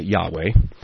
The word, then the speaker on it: Yahweh: Heiser pronounces it Yahweh.